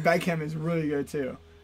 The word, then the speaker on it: Beckham: Beckham. I think the H is silent.